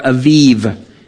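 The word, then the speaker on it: Aviv (אביב): No leavened bread shall be eaten, and on this day you shall go out in the month of Abib," or Aviv is how that's pronounced. The Hebrew word is Aviv.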